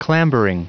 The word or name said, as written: clambering